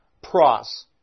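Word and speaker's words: pros (πρός): This word with is the preposition, Greek preposition, pros. In English, we would spell it P-R-O-S, pros.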